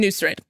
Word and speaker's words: Nusret: I'm sorry, it's pronounced the same way as his name, Nusret.